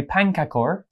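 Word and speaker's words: pannkakor: The first one is Sweden's pankakor. – Very good. Pankakor. That, that, that's how it's spelled anyway. – I mean, pankakor to me sounds like pancake.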